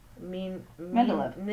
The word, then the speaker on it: Mendeleev: But, so the periodic table started with Dmitry Mendel. I can't say his name. – Mendeleev. – Yeah, thank you.